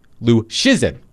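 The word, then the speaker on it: Luszczyszyn: By the way, let me spell Dom's last name for all of you if you have not encountered Dom's work at The Athletic. Lucczyn. Pronounced Lucchesin.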